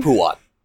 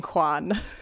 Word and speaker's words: Huon: Before we move further, what is the pronunciation of that dog's name? – Huon. Huon is correct. You are correct. Okay. Yes. – In my head, I just started saying Quan. – A lot of people do.